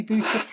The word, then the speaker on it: Boucher: I'm delighted to be welcoming another guest here today, Amy Boucher.